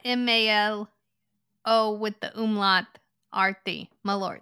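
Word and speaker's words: Malört: Malort. – Yeah, you didn't pronounce that T nearly hard enough. – Malort.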